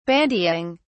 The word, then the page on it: bandying: British English